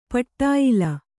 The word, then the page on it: ಪಟ್ಟಾಯಿಲ: paṭṭāyila